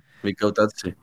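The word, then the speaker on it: Mikautadze: Recomendamos a Kwarashkele, más bien era a Mikautse. Se pronuncia así, ¿no? Mikautse. – Sí, Mikautse.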